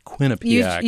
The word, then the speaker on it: Quinnipiac: There was a new poll out this week from Quinnipiac. That's how you say it, Quinnipiac.